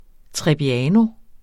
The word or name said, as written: trebbiano